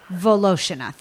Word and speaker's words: Voloshyna: Vol, Vol, say it for me, Anna at Anna Voloshyna Cooks. Voloshyna, thank you. I'm going to spell it. It is V O L O S H Y N A, Anna Voloshyna Cooks.